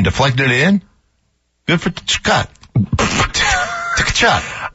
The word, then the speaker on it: Tkachuk: That shot that Tkachuk knocked in. – No, it's Tkachuk. – Tkachuk knocked in. – You just want to pronounce that T, don't you? – The shot that Tkachuk knocked in.